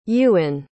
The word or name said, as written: Ewen